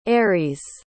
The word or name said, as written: Eirys